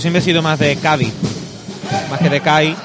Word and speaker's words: Cádiz: El concepto de la ciudad de Cádiz, que en casi toda España pues se pronuncia con las cinco letras. Cádiz, en Andalucía se dice Cádiz y aquí se habla de Cádiz.